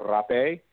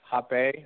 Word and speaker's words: rapé: The other types of plants that we use in a ceremony, one is called rapé, or hapé, or hapalita. It's spelled R-A-P-E, like rape, but an accent on it. So don't get it confused with the way it's spelled.